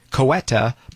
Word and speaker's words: Ceuta: It's spelled C-E-U-T-A, Ceuta, but with a S, an S. So Ceuta, thousands, in fact, roughly 60,000 migrants.